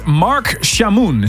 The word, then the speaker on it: Chamoun: And this week, my special guest is Mark Chamoun. Is that the way to pronounce it? – Yeah, that's it.